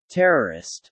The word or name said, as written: terrorist